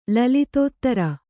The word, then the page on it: ಲಲಿತೋತ್ತರ: Kannada